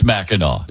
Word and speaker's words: Mackinac: First of all, It's spelled, why do they spell it Mackinac when it's Mackinac? It doesn't make any sense.